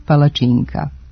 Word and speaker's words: palačinka: Međutim, u novom jednotomnom izdanju ovog rečnika, autori su se opredelili samo za oblik u jednini sa dugouzlaznim akcentom na trećem slogu, koji se odavno ustalio u govoru i glasi palačinka.